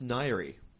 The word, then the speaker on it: Ngaire: And the name is spelt N-G-A-I-R-E. – N-G-A-I-R-E. Nagari. – It's Nairi.